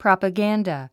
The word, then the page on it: propaganda: prὰpəɡǽndə; プロパガンダ